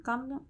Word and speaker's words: Gangnam: Gangnam.